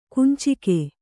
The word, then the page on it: ಕುಂಚಿಗೆ: kuncige